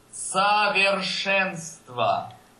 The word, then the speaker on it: совершенство: Okay, that last word that you just said there, that CO, the SO at the beginning, is very, very, very, very far away from the betonte Silbe, from the stressed syllable. – Совершенство.